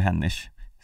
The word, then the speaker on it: Hennig: Nu är det vi som bestämmer vad som är rätt. – Ja, nej, men det är ju Hennish, ska jag säga. Eller? – Ja. Ja, absolut.